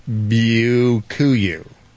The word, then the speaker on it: Byakuya: It's spelled B-Y-A-K-U-Y-A, and it's pronounced Bukkake.